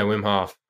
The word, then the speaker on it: Wim Hof: And I said, well, if I'm pronouncing it correctly, Wim Hof. – Yeah, Wim Hof.